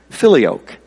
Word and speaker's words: Filioque: Filioque.